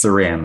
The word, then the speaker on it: Saran: Pretty close. It's Saran, like Saran wrap.